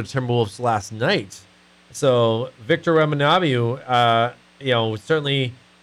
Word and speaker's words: Wembanyama: Wembanyama. There we go. I said it right now.